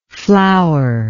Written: flourless

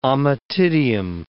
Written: ommatidium

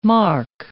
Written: marque

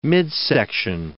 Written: midsection

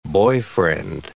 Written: boyfriend

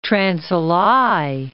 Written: Trans Alai

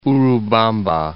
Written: Urubamba

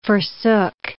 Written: forsook

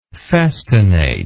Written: festinately

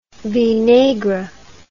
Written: vinaigre

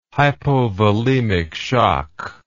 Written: hypovolemic shock

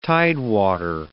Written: tidewater

